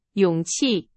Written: 勇气

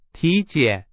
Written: 体检

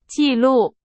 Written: 纪录

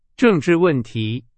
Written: 政治

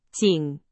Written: 仅

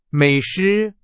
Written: 美食

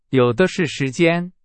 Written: 有的是

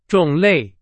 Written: 种类

